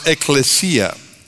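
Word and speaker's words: ekklesia (ἐκκλησία): The word that is translated in our Bibles, church, in the New Testament, comes from a Greek word that we could pronounce ekklesia.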